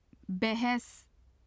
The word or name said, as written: bahs